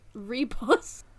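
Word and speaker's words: Ribos: Transplant, much more enjoyable than the Reboss operation. I said it wrong last time, because how was I supposed to know that it's pronounced Reboss? – English is a wild language.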